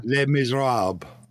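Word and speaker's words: Les Misérables: Les Miserables or something? It's whatever. – Les Miserables.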